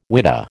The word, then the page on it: whidah: American English